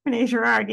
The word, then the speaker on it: Rene Girard: Rene Girard.